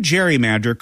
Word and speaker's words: gerrymander: But when the term gerrymander caught on, it wasn't with the hard G sound of Gerry's surname.